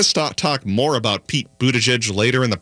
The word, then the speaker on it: Buttigieg: Locally, Mayor Pete Buttigieg, I've finally learned how to pronounce his name. It's Buttigieg, if you can't really say it very well, or I kind of think of it as saying Buttigieg.